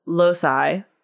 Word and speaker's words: Loci: In fact, the introduction to Loci, am I pronouncing that right? – Yes.